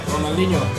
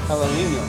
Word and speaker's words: Ronaldinho: Oh, Ronaldinho. – Ronaldinho.